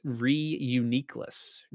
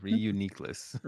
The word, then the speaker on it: Reuniclus: It's Reuniclus. – Of course, yeah. Reuniclus.